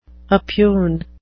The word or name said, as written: oppugn